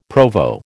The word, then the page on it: Provo: American English